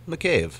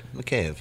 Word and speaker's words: McCabe: That's all right. McCabe. Sounds more Irish than Russian.